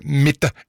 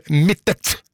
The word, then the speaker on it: Mittet: I anledning Eurovision-finalen, og så påpeker det Anne Soli at Mitte et stedsnavn I Rauma kommune, og navnet har alltid blitt uttalt med en tydelig T til slutt, akkurat slik det skrives. Derfor det ganske frustrerende å høre Mitte I stedet for Mittet.